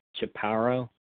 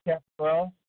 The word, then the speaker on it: chaparral: But what they don't know is burdock, chaparral, how you spell that? How you pronounce that? Chaparral? – Chaparral. – Chaparral, yeah, chaparral.